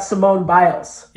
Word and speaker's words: Simone Biles: I would, what about Simone Biles? – Yeah.